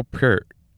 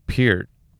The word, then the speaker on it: Peart: First drummer on the list is Neil Peart. Well, see, I, I screwed up the name already. The actual pronunciation is Peart, but I've always known everybody's always known him as Neil Peart of Rush.